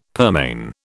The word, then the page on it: pearmain: British English